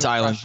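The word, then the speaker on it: Eiland: Very impressive about Island, or Elin, whatever how you pronounce his name. So we got him. – It's Island.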